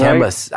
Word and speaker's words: Camus: Camus.